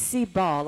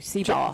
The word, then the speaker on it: Seebaugh: It does not have a G sound. It is Seebaugh, like B-A-H or B-A-W. Seebaugh.